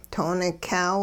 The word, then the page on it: too-nikyaaw: hupa